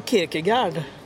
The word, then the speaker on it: Kierkegaard: Som Kierkegaard drog igång på 1800-talet. – Just det, ja. – Och som han sa då att existentialismen... – Menar du Kierkegaard? Jag är väldigt noga med uttal.